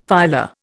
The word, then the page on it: phyla: Indian